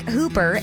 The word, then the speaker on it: Hooper: shout out to you in Hooper, the city of Hooper, spelled like Hooper, H-O-O-P-E-R, but it is Utah. So we gotta throw it, say it a little bit differently, you know, switch it up.